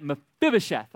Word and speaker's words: Mephibosheth: His name is Mephibosheth. Mephibosheth. Mephibosheth. All right, you got that? Mephibosheth.